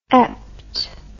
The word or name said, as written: eptitude